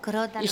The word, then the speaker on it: crótalo: C-R-O, tal y como... – O-T-A-L-O, crótalo. – Crótalo, vale, sí, perfecto, ya no se me olvide, con tilde, es la O, claro. Es esdrújula, ¿no?